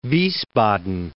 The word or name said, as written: Wiesbaden